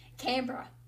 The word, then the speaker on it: Canberra: Canberra? How do you say it? – Canberra.